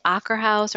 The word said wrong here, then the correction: Akershus: Or, Akershus.